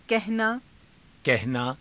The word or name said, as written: kahanaa